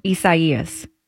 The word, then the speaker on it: Isaias: We had a ruling, by the way, on the correct pronunciation of the hurricane. What's what are we calling this thing? – Isaias. – Well done.